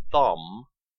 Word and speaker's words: thumb: The silent B in thumb and limb is strange, since these words never had a B sound.